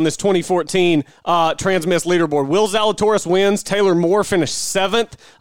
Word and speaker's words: Zalatoris: A guy by the name of Will, how do you pronounce this? Zale Torice? Will Zalatoris.